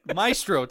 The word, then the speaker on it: Maestro: Next week, we are reviewing Maestro. – You butchered that so bad. Maestro. – Yeah, Maestro. – You overthought it. – I really pronunciated the A-E-S there, really.